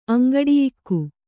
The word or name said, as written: ಅಂಗಡಿಯಿಕ್ಕು